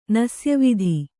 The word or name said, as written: ನಸ್ಯವಿಧಿ